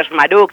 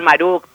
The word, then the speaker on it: Schmaruk: Mira, yo lo pronuncio Schmaruk, pero en realidad se pronuncia como sale, porque en el español tres consonantes seguidas es muy difícil. Uh-huh. Así que yo lo digo Schmaruk, se puede decir Maruk, pues como te salga, está bien.